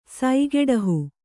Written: ಸೈಗೆಡಹು

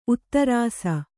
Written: ಉತ್ತರಸ

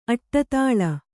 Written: ಅಡಿದಳ

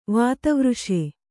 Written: ವಾತವೃಷೆ

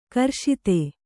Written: ಗ್ರಸ್ತ

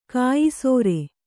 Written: ಕೆಯ್ಸೇರ್

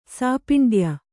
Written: ಸಾಪಿಂಡ್ಯ

